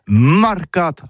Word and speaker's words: marcat: Mais en gascogne toulousain, c'est prononcé sur les marcates, avec un A marcat.